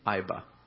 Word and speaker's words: Iba: The city happened to be spelled I-B-A, Iba. But as it's pronounced there, Iba.